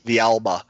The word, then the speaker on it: Villalba: with Atlanta having Hector Villalba, I never can say his name right. – Villalba, and then Almiron.